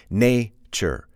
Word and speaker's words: nature: For example, nature. You can hear the emphasis, it's on the first syllable, nature.